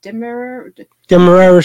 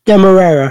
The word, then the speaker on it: Demerara: Black spiced rum bursting with deep, I never know how to say it, Demerara sugar. – Yeah, the brown sugar. – Demerara. – Brown sugar. – Demerara.